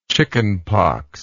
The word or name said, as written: chickenpox